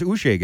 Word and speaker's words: Uche: By the way, he waits 3 years to tell us how to pronounce his name? That's a little weird, right? – Yeah. Well, and yeah. – Isn't that like, first year, why don't you say, hey, it's Uche, guys?